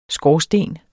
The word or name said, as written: skorsten